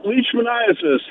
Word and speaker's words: leishmaniasis: Hey, so leishmaniasis, that's how it's pronounced, the disease transmitted by sand fleas.